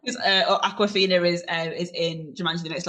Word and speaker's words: Awkwafina: Is it Awkwafina, is that how I say it? – Awkwafina, okay.